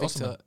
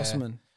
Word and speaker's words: Osimhen: Victor Osimhen. – Yeah. – Osimhen.